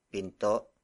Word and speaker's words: pinto: Word for word it's bumukas ang pinto ng bahay. Bumukas ang pinto ng bahay.